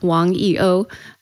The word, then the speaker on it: Wang YiOu: Um, in Mandarin, it would be pronounced Wang YiOu. Um, in Chinese, you use your family name first, and the language of Chinese is pictorial.